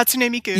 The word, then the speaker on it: Hatsune Miku: Like Hakune Mitsu? – Yeah, Hatsune Miku. – Yeah. Okay, there we go. Never mind. Yeah, I knew I got it wrong somewhere. – You had all the right syllables.